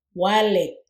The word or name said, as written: walig